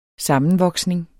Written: sammenvoksning

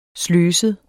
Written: sløset

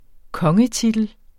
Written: kongetitel

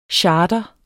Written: chartre

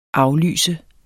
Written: aflyse

